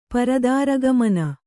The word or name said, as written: ಪರದಾರಗಮನ